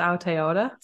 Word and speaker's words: Aotearoa: I'll just say, if I'm saying it right, Aotearoa. – I can never say it. It's Aotearoa.